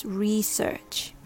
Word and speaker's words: research: Pronunciation number one, stress on the first syllable. It's more common in American English. Research. The first part, re, sounds like re as in read, followed by search, which sounds like search, searching for something.